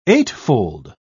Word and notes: eightfold: British English

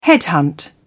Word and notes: headhunt: British English

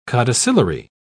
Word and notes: codicillary: British English

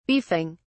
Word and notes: beefing: British English

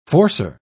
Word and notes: forcer: British English

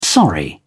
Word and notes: sorry: British English

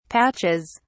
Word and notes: patches: British English

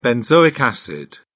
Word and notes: benzoic acid: British English

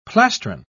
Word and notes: plastron: British English

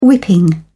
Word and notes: whipping: British English

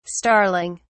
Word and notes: starling(s): British English

